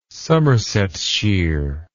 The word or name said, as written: somersetshire